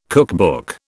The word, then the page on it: cookbook: British English